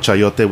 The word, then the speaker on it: chayote: So we're playing around with chayote, which is a summer squash, or pronounced sayote in the Philippines.